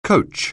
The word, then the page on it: coach: anglais